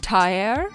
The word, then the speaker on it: Terre: and it is more pronounced like Terre.